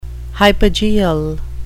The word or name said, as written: hypogeal